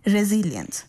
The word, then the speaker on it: resilience: इसकी जो स्पेलिंग है वो है RESILIENCE और इसको बोलते हैं रेजिलियंस।